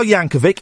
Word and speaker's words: Yankovic: We've got Weird Al Yankovic. It's a hard C at the end.